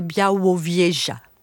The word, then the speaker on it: Białowieża: nous allons partir pour la forêt de Białowieża. Je ne sais pas si je le prononce bien. – Alors à la française, oui. À la polonaise, c'est Białowieża.